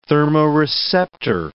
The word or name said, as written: thermoreceptor